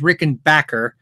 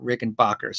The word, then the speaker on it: Rickenbacker: So, you know, if you look at the official How Do You Say video, it is Rickenbacker, not Rickenbacker.